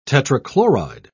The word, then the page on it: tetrachloride: British English